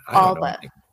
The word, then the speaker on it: Alba: Alba.